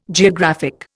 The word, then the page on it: geographic: Indian